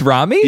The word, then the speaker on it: Romy: Like Romy, it's actually Romy. I forgot. We gotta pronounce it correctly, because my girlfriend's best friend's name is Romy, and she was devastated to learn that Romy was actually Rami.